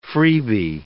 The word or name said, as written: freebee